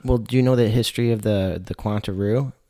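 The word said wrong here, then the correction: Quintana Roo: Quintanaroo? – Quintanaroo.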